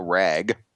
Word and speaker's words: Wragg: A woman by the name of Samantha Ragg. I'm not kidding. – Okay. – It's spelled W-R-A-G-G, 28.